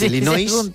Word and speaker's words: Illinois: Es un estado de Estados Unidos que se pronuncia así. Es Illinois, pero es Illinois.